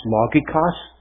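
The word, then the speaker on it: logikos (λογικός): The Greek word that's used here is a Greek word that would be pronounced logikos.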